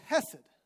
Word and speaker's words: hesed: H-E-S-E-D is the transliteration. Say that, hesed. Hesed. Now say it like you're speaking Hebrew, which means you like need to get like clear out all your lungs, like hesed.